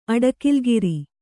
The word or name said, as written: ಅಡಕಿಲ್ಗಿರಿ